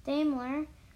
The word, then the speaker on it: Daimler: A German engineer named Gottlob Daimler, if I'm saying that correctly. – Daimler.